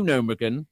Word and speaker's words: Gnomeregan: So now they've got new Gnomeregan. It'd be spelled GG. You spell it GNU. Gnomeregan.